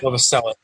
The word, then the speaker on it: Novoselic: Am I saying it right? The bass player? Novoselic? – Novoselic.